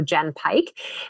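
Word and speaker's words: Jenn Pike: They can just check out Jen Pike. And then Instagram, lots of info every single day. My name is spelt with two N's, so Jen Pike.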